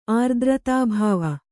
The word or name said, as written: ಆರ್ದ್ರತಾಭಾವ